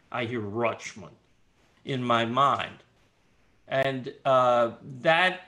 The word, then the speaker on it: Richmond: I have in my mind the association Richmond-Rochmond, because that's how the real Richmonders pronounce it, Rochmond.